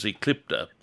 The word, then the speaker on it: Eclipta: I mentioned that last week, and I won't try to spell it, but botanically, it's known as Eclipta, E-C-L-I-P-T-A, Eclipta alba.